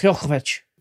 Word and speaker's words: Djokovic: Novak Djokovic.